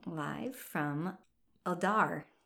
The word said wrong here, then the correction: Ulduar: U-L-D. Uldu, uh, You're close. Ulduar. Yeah, Ulduar. Ulduar. Yes, live from Ulduar.